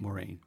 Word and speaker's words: Moraine: So anyway, at Lake Louise, she said, why don't you go to Emerald Lake since Lake Moraine, did I say it right? – Moraine.